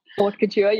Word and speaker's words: haute couture: Haute couture, how do you pronounce that? – Haute couture, yes.